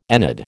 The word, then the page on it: ennead: American English